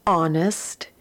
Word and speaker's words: honest: The words are hour, honor, honest, air. Second column, ghost, ghastly, ghetto, ghoul. And the third column, rhyme, rhymed, rhyming. And again, all of those words have the letter H, but that H is silent.